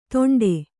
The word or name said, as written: ದೊಂಡೆ